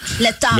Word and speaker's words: L'étape: L'étape by la Tour de France. ¿Lo dije bien? – L'étape. – Lo dije bien, l'étape by la Tour de France.